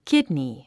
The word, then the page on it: kidney: kídni; キドニィ